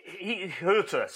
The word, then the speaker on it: Hütter: How do you pronounce Hütter? Over to our German expert. – Ralf Hütter. – It has an umlaut over the U, so that...